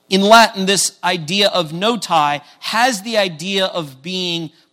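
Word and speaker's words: Notae: And so today, the title is No Tie, T-A-E says tie, Ecclesiae. And again, the A-E on the end makes it a long I sound. So say this with me, if you would. No tie, Ecclesiae.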